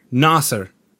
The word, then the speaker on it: Naser: That's the most French way to pronounce my name, but I'm assuming you're a Quebecois. Are you a Quebecois? Or no, I'm not. Naser. – Naser.